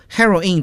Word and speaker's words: heroine: 所以我们一说 heroine 这个发音，听起来好像又像女英雄女主人公，也像海洛因啊。